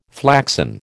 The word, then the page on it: flaxen: American English